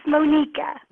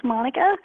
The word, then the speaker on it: Monica: And so Monica is the Indian pronunciation of the name. So actually, officially, it is spelled Monica, but it's pronounced Monica.